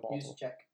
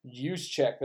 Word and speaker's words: Juszczyk: Uh, I, I always butcher this guy's name. Uh, it's your boy over there that used to have him. – Juszczyk. – Use check. That's the guy.